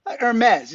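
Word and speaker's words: Hermès: Am I saying it right? – It's Hermes. – Hermes.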